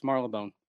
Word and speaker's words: Marylebone: I'm sure you will get tons of advice, but I would suggest you set up base in Marylebone. Am I saying that right? – Marylebone. I believe it's Marylebone.